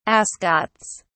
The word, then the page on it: ascots: British English